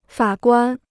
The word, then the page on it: 法官: fǎ guān